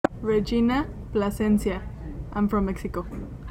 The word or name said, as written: Regina Plascencia